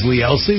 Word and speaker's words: Lee Elci: My name is Lee Elsie. Let me spell it out for you. L-E-E-E-L-C-I.